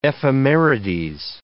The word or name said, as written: ephemerides